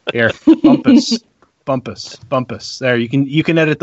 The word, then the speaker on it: Bumpass: Did you guys cover Roger Bumpass at all? I know I'm saying his name wrong. – Yes, so Roger Bumpus is the voice of Squidward from SpongeBob SquarePants.